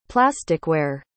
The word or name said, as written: plasticware